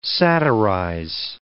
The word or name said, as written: satirize